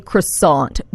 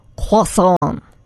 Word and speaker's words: croissant: So we say croissant, but the proper way to say it, the French way to say it, is croissant.